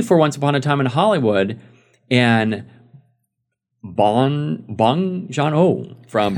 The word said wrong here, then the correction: Bong Joon Ho: Bong Joon Ho.